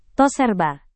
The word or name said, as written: toserba